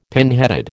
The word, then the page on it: pinheaded: American English